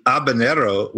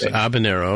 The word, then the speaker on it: habanero: But the habanero, which is spelled starting with an H, the H is silent. It's kind of a confusing thing. – Oh, it's habanero, okay.